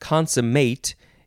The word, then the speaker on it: consummate: Next word is the second form of this, but it is pronounced consummate.